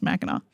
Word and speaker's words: Mackinac: but it is Mackinac. So if you're looking at the show notes, don't worry, we didn't misspell it. It ends with a C, but it's pronounced Mackinac.